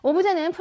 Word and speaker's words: 오브제: 오브제는 프랑스어 오브제. 이거 발음이 오브제예요, 여러분.